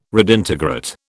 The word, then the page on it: redintegrate: British English